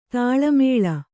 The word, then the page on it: ತಾಳಮೇಳ: Kannada